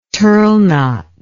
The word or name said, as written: turle knot